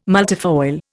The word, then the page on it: multifoil: Indian